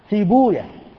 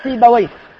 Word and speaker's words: سيبويه: وجاء عندنا كما حكى عالمان جليلان استخدام زعم بمعنى قال، أول هذين في بوية إمام أهل اللغة، ولعلكم تستغربون نطقه هكذا، ينطقه المحدثون سيبويا، وهو مشهور عند الناس سيبويه، وإلا المحدثون يقولون سيبويا وحمدويا وراهويا ونفقويا وخالويا وهكذا إلى غير ذلك. لماذا؟ لأنهم سمعوه سماعا، وهذه أسماء أعجمية، فلما كانت أعجمية عدنا فيها إلى لغة أهلها نسمع كيف ينطقونها فننطق كما نطقوا، وهم ينطقونها سيبويا وحمدويا وخالويا ونفقويا وراهويا فلم يتعدوا السماع.